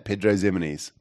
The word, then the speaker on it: Pedro Ximenes: I know it's an X, not the Z, but that's how you say it, Pedro Ximenes.